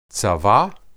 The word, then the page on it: tsaba: hebrew